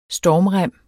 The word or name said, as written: stormrem